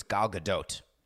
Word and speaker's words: Gal Gadot: and in Hebrew it's Gal Gadot. So she may pronounce her name Gal Gadot, but definitely not Gal Gadot.